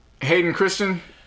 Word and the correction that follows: Christensen: Hayden Christensen.